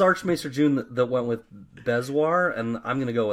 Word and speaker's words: bezoar: We got about our bringing up of Bezor. Am I saying it right now? Bezor. – Well, we got different feedback. It's hard to say. The different first feedback we got was Bezoar.